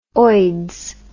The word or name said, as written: oids